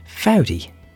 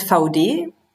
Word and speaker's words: Vaude: Managing Director of German hiking and biking company Vaude, or, as you'll soon find out, how it should be pronounced, Vaudi.